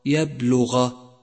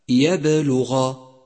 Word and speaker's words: يبلغ: إذا أهملتها لا يتضح نطق الحرف، أي هكذا يبلغ يبلغ، فتأتي القلقلة فتقوي الحرف فيصبح لفظه هكذا يبلغ يبلغ.